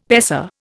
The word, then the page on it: paisa: Indian